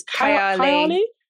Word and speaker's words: Kayali: It's Kayali.